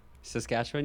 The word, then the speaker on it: Saskatchewan: I do have other Canadian friends, so shout out to my friend Janae, who lives in Saskatchewan. Did I say that right? – Saskatchewan? Yeah. – Yeah.